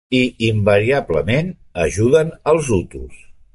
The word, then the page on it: invariablement: catalan